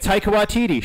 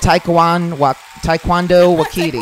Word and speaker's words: Taika Waititi: Taika Waititi. Shout out to Taika Waititi. – Shout out to Taika Waititi. – There we go. – Okay. I said it wrong. I butchered it. I said Taekwondo Waititi.